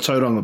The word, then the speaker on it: Tauranga: So, beautiful pronunciation of Tauranga, by the way.